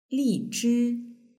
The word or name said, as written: lizhi